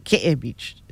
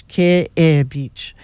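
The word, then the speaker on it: Ke'e Beach (Kēʻē): K, the letter K, the letter E with a Makron, Okina, the letter E with a Makron. Okay? Ke'e Beach. Not Ke'e Beach. It's Ke'e Beach.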